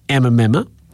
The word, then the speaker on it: Emma Memma: I always feel like I'm saying it incorrectly, but it is Emma Memma.